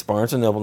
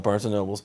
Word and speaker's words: Barnes & Noble: I agree, I was saying it wrong. It's Barnes & Noble, not Barnes & Nobles.